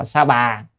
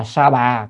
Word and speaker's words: Sa Bà: cho nên đọc là Sa Bà, Sa Bà hay Ta Bà cũng vậy thôi, theo từ điển thì là Sa Bà,